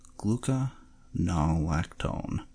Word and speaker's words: Gluconolactone: Tritoseth 10, propanentiol, camphor, menthol, citric acid, gluconolalkane. That was a bad pronunciation. Let's try this again. Gluconolactone, sodium benzoate, calcium gluconate, sodium citrate, limonin, linalool.